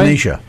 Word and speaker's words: Benicia: Your gift from Benicia. Did I say that right? – Benicia, uh-huh.